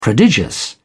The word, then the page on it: prodigious: British English